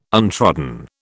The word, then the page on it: untrodden: British English